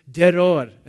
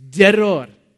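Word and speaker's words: deror (דרור): The Hebrew word is deror. And you need to roll your R. Can you guys, do we have any R rollers here? It's deror. That's how they say in Hebrew.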